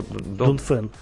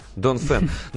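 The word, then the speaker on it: Dongfeng: вот как ты говоришь еще? – Донфен. – Донфен. Но, понимаешь, да, людям в России тяжело это произносить.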